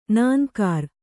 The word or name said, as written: ನಾನ್ಕಾರ್